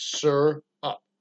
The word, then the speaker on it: syrup: But I actually hit the pronunciation on you can't hit on the thing, but I looked it up and they showed the pronunciation. It's sir up.